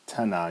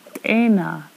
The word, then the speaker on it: tena: So I'm noticing you're not saying tana, you're saying t. How do you pronounce that T? – It's te, tena. So it's a little flatter and...